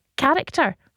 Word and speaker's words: character: Surely the real question is, why is there an H in character? It's pronounced character.